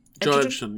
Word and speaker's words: Edgerton: Is that how you say his name? – Edgerton, yeah. Okay, well, Tom Hardy is way—he's way too intense.